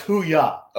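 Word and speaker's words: hooyah: So it's pronounced hoo-yah.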